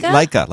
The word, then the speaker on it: Licea: M-E-L-K-O-R-K-A. Melkorka, L-I-C-E-A. Lycia. Melkorka. – Lycia? – Lycia. Yeah. She's like the camera. – Leica, like the camera.